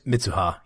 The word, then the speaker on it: Mitsuha: We get in the first 20 minutes of the movie such an abundance of character development of both Taki and Mitsuha. I want to make sure I'm pronouncing it. Is it Mitsuha? Okay. – Mitsuha. Yeah. Something like that. – Okay. – Yeah. It's a suha, not a shu.